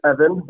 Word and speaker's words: Ervin: So my name is Ervin with the silent R, and I run the R Silent Podcast.